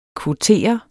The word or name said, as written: kvotere